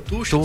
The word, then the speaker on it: Tuchel: É, mas o treinador inglês também, como que é a pronúncia? Eu falo Tuchel. – Thomas Tuchel, Tuchel. – Tuchel, tá bom.